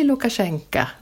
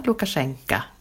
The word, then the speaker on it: Lukashenka: Men då är det så att, det är lite krångligt, ett obetonat O i slutet på ett ord så här, uttalas som A på ryska. Så att uttalet blir Lukashenka om man pratar ryska.